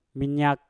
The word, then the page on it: minyak: Mpur